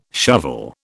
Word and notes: shovel: British English

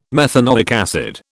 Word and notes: methanoic acid: British English

